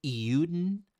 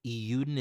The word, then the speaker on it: Eiyuden: I'm surprised that Sleek hasn't mentioned Eudon, I think is how you pronounce the name, EIYUDEN, Eudon.